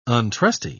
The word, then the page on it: untrusty: British English